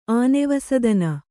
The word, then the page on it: ಆನೆವಸದನ: Kannada